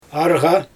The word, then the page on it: arGa: Xyzyl